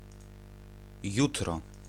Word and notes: domani: /doˈmaː.ni/; /dɔ.ˈma.ni/

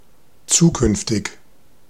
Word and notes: zukünftig: /ˈʦuːˌkʏnftɪç/

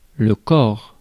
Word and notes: corps: [lə kɔʁ]; France

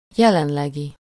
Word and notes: courant: [ku.ʁɑ̃]; France

